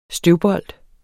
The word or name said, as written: støvbold